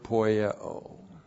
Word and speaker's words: poieo: And then the verb poieo. Which deeds? Diotrephes is poieoing. P-O-I-E long O. Poieo. And poieo is the verb to do, or to make, or to manufacture, or to produce.